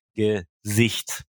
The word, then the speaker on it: Gesicht: Aber es gibt auch ähm, Wörter, die werden auf der hinteren Silbe ähm, betont, wie zum Beispiel Gesicht.